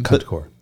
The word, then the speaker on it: Concours d'Elegance: Is that, am I saying that right? – Concours.